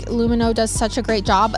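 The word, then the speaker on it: Lumineux: That's Lumino. Remember, spelled with an X, so you can X out the harm. Lumino. That's L-U-M-I-N-E-U-X.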